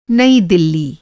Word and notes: नई दिल्ली: Hindi

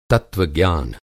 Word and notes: तत्त्वज्ञान: Hindi

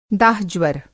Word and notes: दाह-ज्वर: Hindi

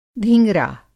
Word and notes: धींगरा: Hindi

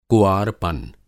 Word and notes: कुआरपन: Hindi

